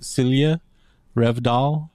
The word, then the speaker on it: Silje Revdal: I think she's from Norway, and her name, I believe, is pronounced Silje Revdal. I had a friend, our German friend Silke. It's kind of pronounced. It's written the same, except a J instead of a K. Silje or Silke. Revdal.